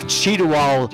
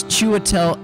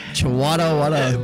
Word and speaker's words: Chiwetel: Well, if Cheetawal Ejiofor wins. – What? A Cheetawal to die for? To die for a proper pronunciation. It's Chiwetel Ejiofor.